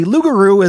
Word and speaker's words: Lugaru: The Lugaru is also a term used to describe a Soucouyant, and is pronounced almost identical to the French term for werewolf.